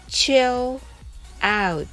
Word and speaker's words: chill out: チェオのチの発音は唇を尖らして息と一緒に発音してくださいはじめはゆっくりです chill out